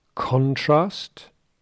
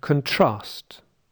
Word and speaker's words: contrast: First, listen to the difference between a stress in the first syllable and a stress in the second syllable. Those are the words contrast and contrast.